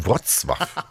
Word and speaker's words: Wrocław: Wrocław.